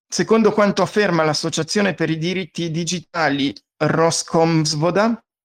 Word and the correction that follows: Roskomsvoboda: non l'ho detto giusto, aspetta, Roskomsvoboda,